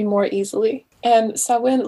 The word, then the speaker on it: Samhain: samhain i believe is how you pronounce it and like so many other uh,